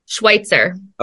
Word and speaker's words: Schwytzer: Schwytzer.